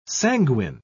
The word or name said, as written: sanguine